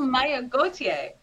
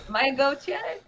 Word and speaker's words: Mayagoitia: We have Aline Mayagoitia. I said that wrong, but Mayagoitia. That Goitia there. I'm going to say it right.